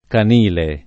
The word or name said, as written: canile